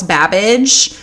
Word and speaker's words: Babbage: And Somerville introduced Ada to very famous British inventor by the name of Charles Babbage, which, like, I love because it rhymes with cabbage,